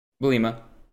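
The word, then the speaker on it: Bielema: And early on in the season, we didn't get to see much of him because of the coach, because of Bulimi. Or am I saying that right? Bulimi or Bulima. – Bulima. I knew I was messing up a little bit, but Bulima.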